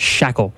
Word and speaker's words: Schachle: Uh, it's it's spelled S C H A C H L E, and it's pronounced shackle.